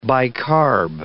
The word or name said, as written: bicarb